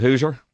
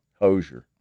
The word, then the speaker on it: Hozier: Would you call it Hoosier? – It looks more like Hoosier. – Hozier? – Hozier. – Hozier. – Hoser. – Hoser. – I did have to ask him how you say it. Hoser.